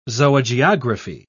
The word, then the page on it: zoogeography: British English